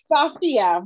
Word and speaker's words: Sophia: Am I saying it correctly? – It's Sophia. Rhymes with Mafia.